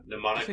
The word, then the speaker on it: mnemonic: Is that how you pronounce that? – Mnemonic vestment. Mnemonic? – PN. – Yeah, that's mnemonic vestment.